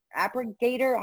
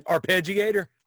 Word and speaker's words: arpeggiator: The appregator? How do you say this word? – Arpeggiator.